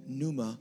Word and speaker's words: pneuma: Let me give you a verse in the New Testament where the word spirit, aka pneuma, was, re- was, was, was, was penned. That's a silent P, all right?